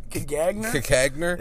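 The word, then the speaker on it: Caganer: Okay, how would you pronounce that word? – Cajoner. – Okay, Cajoner or Cagagner? – Cagagner. – It's C-A-G-A-N-E-R.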